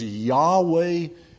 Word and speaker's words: Yahweh: It's the joining together of sometimes it's pronounced Jehovah, but the better way is Yahweh and the verb to save.